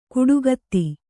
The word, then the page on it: ಕುಡುಗತ್ತಿ: kuḍugatti